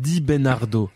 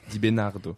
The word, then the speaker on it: Di Bennardo: Di Bernardo. Di Bernardo. Ça le va. On dit on veut dire Bernardo c'est ça.